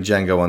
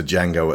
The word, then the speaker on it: Django: He, he sidles up to Django at the bar and says, how do you spell that? The D is silent.